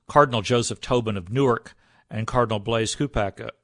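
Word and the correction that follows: Cupich: of Chicago. Kupich, I can never pronounce that name.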